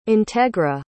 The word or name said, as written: integra